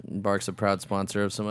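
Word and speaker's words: Barq's: Is it Barks or Boggs? – I think it's Barks. – It's a... Does a Q sound like a G? – I think it sounds like a K. – Oh, fuck. – Like bark like a dog.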